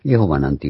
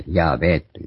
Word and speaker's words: ヤハウェ: だから、エホバなんていう、エホーワーなんていうのは間違った読み方だと。一部サマリア語訳にも、ヤーベーという発音で、ギリシャ語で書かれたりしてるから、で、今日は、ヤーベー、なしはヤーベーということが、まあ、正しい発音だったろうと。で、主なる神って、それに、ヤーベーという名を持ったエロヒム、神だと。